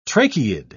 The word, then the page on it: tracheid: British English